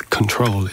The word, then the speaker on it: CKTRL: He records as Control. It's spelt C-K-T-R-L, all lowercase.